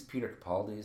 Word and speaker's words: Capaldi: And I've only seen one season of, I think it's Peter Capaldi is how you pronounce his name. – Yeah.